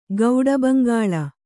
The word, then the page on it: ಗೌಡಬಂಗಾಲಿ: kannada; gauḍa baŋgāli